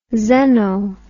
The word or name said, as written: xeno